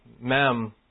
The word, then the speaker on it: mem: So this section is the mem strophe, and that's how you pronounce it.